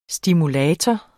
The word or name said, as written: stimulator